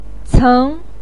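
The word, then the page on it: ceng2: tone 2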